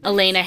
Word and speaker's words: Elayna: So I am at Erin Desner on TikTok and at Elena Harrison on Instagram. My name is spelled E-L-A-Y-N-A.